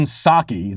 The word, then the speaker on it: Psaki: EN says, it's pronounced Jen Psaki. The P is silent, man.